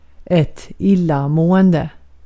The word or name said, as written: illamående